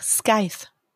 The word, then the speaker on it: Scythe: Und die Entscheidung über Leben und Tod treffen die, und jetzt kommt der Titel des Buches, Saitche. Ich hoffe, ich habe es richtig ausgesprochen. Englisches Wort für Sense. – Ach, Scythe.